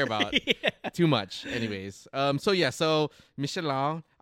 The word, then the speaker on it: Michelin: So yes, Michelin is the proper English pronunciation. – Yeah. – But the French one is Michelin.